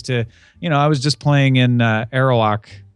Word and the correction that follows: Arrakis: Oh, the Spires of Arrakis.